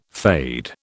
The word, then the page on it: fade: British English